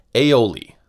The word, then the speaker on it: aioli: Cooks in Provence combined the French words for garlic and oil to form, as we pronounce it in English, aioli.